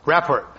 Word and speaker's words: Rapport: Our guests are Zach Wasserman, chair of the Bay Conservation and Development Commission, as a report. Am I saying that right? – Rapport. – I knew I wasn't saying it right.